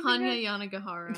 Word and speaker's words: Hanya Yanagihara: Hanya Yanagihara.